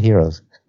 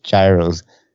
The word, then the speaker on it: gyros: They all call it heroes. they don't call it gyros. They call it heroes out there.